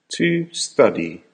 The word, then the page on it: to study: American English